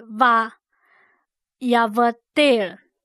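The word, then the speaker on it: va yavdel (וַיַּבְדֵּל): 读音是 va yavdel va yavdel。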